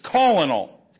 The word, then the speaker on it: colonel: But it's like, it's not spelled colonel. It's spelled colonel. What is wrong with English sometimes? I don't get colonel out of that at all. It's colonel. Colon.